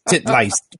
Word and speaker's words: Titleist: Listen, my German teacher was like, "It's titliest, titliest, titliest." yeah, that's how you say it. Look at how it's spelled.